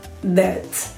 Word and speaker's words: debt: Debt. – Debt. Debt. – No, debt. Que además es súper difícil. – Debt. Es una glosa. Stop. Sí, pues la B es muda en muchas palabras.